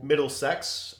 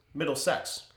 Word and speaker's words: Middlesex: Middlesex, I believe it's pronounced. – It's, I don't know how to pronounce it. Middlesex? – Middlesex. – Yep, that's exactly how it's spelled.